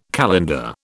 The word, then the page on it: calender: British English